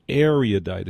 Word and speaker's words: erudite: Now, you know, I got a nasty note saying I was mispronouncing that. Apparently, the pronunciation is erudite. It isn't E-U-R. It's how do you say it? – Erudite. – It is. There's a syllable there, right? – Yes.